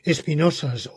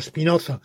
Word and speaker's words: Spinoza: Evidentemente, el panteísmo de Hegel, el de Schelling, no son exactamente como el panteísmo de Spinoza, pero estaban inspiradísimos en Spinoza o Spinoza, como ustedes quieran pronunciar.